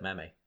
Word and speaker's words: meme: People don't know that he specifically said, I don't care if it's because it's based off the spelling of gene, you pronounce it meme. You don't pronounce it like gene.